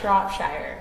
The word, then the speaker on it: Shropshire: I'm like, is the P silent or? – Shropshire.